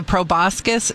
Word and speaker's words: proboscis: And I remember last time you were here, we were talking about, it was like the proboscis.